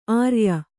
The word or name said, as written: ಆರಯ್ಯು